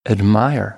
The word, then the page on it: admire: American English